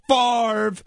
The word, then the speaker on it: Favre: And I remember saying, just yelling to people, Marv rhymes with Favre. Marv rhymes with Favre.